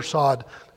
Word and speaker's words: sod: The deepest level of that is called sod or sod, S-O-D is pronounced in different ways.